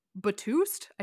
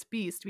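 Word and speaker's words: B2ST: I thought of like all the like K-pop bands that I used to listen to back in the day and was like, Batoost? I don't understand. Oh, it's Beast because 2 is like pronounced E.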